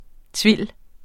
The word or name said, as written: tuile